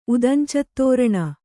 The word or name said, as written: ಉದಂಚತ್ತೋರಣ